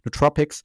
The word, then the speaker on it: Nootropics: Nootropica heißen die neuen, Nootropica im Englischen ausgesprochen, Nootropics, ähm, die eben meine geistige, mentale Leistungsfähigkeit verbessern.